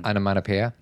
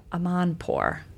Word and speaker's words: Amanpour: Amanpour. – No. – Oh. – Amanpour.